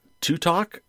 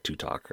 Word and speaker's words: ToTok: there is an app called TuTok. I think it's how you pronounce it. T-O-T-O-K. That's capital T, little O, capital T, little O, K. So I think it's supposed to be like TuTok or something like that.